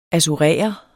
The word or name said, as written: assurere